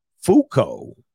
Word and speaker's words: Foucault: Shout out to the Notorious N-I-G again. – I'm ready. – He says, coach's name is pronounced Michelle Foucault.